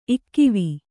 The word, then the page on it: ಇಕ್ಕೂವೆ: ikkūve